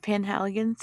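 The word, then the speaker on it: Penhaligon's: Is it Panhaligon's? – Yes, that's it.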